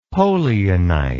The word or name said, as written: polianite